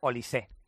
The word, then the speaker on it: Olise: Por cierto, nos están diciendo varios oyentes, que en la TEF-AN francesa pronuncian Olise. – Olise. – Con acento en la E.